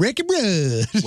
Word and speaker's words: Ricky Rudd: Hey, you didn't just say Ricky Rudd, you said Ricky Rudd. – Which is how you're supposed to say it, which is exactly how it's pronounced.